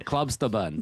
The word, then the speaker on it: Klubsterben: You didn't really lean into the German pronunciation. – No, I don't want to try. – It did kind of sound Californian, but yeah, Klubsterben. Das ist der richtig, Lena.